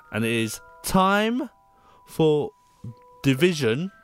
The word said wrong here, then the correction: Divination: Divination. – Yeah, which... – Don't just go, yeah, you said it wrong twice.